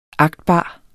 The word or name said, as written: agtbar